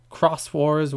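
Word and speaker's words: Xros Wars: It doesn't really start going off the rails until Savers and Cross Wars, which is weirdly spelled because, you know, Japan. It's Digimon X or OS Wars, which I'm pretty sure is to be pronounced Cross Wars, but if you were to pronounce it phonetically, it's like Cross Wars.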